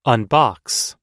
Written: unbox

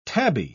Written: tabby

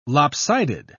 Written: lopsided

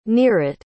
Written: nirit

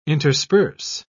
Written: intersperse

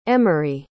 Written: emory

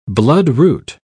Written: bloodroot

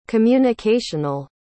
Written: communicational

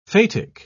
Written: phatic

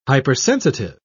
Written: hypersensitive

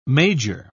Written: major

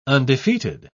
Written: undefeated

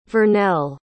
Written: vernelle

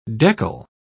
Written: deckle

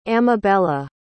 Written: amabella

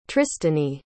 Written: tristany

